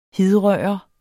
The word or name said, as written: hidrøre